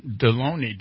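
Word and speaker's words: Delony: On the line is Dr. John Deloney. Did I pronounce that right? – Yes, sir. It's like baloney, except with a D.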